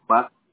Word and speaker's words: Paz: Estuardo Paz Alvarado. – Okay. Very nice. Paz Alvarado. P-A-Z, ¿verdad?